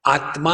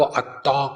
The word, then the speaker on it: आत्मा: बंगालियों में आ, जो कंजंग कॉन्सेंट होता है जोड़ाक्षर वो ठीक से उच्चारण नहीं कर सकते जैसे आ, आत्मा होगा तो आत्मा को अत्ता कहेंगे तत्वमसी होगा तो तप तमसी कहेंगे